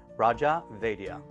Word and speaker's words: Raja Vaidya: Raja Vaidya.